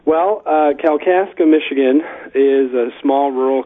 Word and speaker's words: Kalkaska: I want to say welcome to our guest today from St. Paul Lutheran Church in Kalkaska, Michigan.